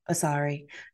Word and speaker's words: Asare: So in my stalking of your LinkedIn page, I see that you are Dr. Doctor, can you, how do you say your last name? Asare? – Asare.